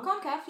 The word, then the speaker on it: CONCACAF: But we do have women's CONCACAF, or I think I probably pronounced that wrong. – CONCACAF, yeah. – CONCACAF? Okay.